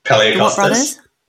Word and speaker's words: Palaiokostas: Palaiokostas.